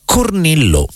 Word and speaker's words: Cornilleau: Era Cornillo, perché penso io quella pronuncia francese, così, è scritto Cornilleau,